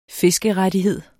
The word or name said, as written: fiskerettighed